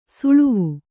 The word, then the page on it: ಸಳವ: saḷava